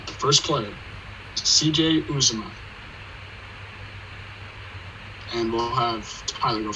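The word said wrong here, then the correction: Uzomah: Okay, so first off, it's pronounced Uzoma.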